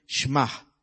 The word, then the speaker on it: Shema: Es notoria que la palabra que se utiliza allí Es la palabra que utilizan los hebreos en el Shema, así se pronuncia.